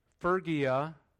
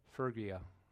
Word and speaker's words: Phrygia: And then verse 6, they go through Phrygia. Man, I had to listen to that on my Bible app. That's a tough word to pronounce. Phrygia. All right, everybody say it together. Phrygia.